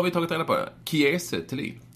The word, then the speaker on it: Kiese Thelin: Man ska uttala det Kiese Thelin. – Jaha, okej, okay, jag har sagt Kiese. – Inte Kiese Thelin, utan Kiese Thelin.